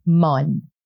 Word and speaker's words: main: Main, hand. – Uh-huh. – We would say mon.